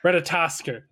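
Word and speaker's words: Ratatoskr: Ratatouille. No, it's Ratatouille. – That's what we call it. Yeah, basically it's Ratatosker. – Ratatosker. That's how it's pronounced.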